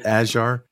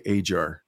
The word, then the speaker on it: Ajar: I say it Ajar, but he's very quick to correct me and say Ajar, like it's Ajar of peaches or something.